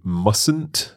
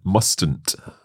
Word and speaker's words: mustn't: Notice though, when we say mustn't, there is a silent letter in there, isn't there? – That's right. You say mustn't, not mustn't. – That's right. So that T is silent. We don't pronounce it.